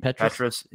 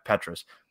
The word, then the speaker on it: Petras: Petras. – Yeah, Petras.